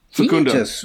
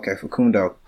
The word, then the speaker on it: Facundo: Facundo. – Facundo.